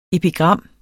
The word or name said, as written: epigram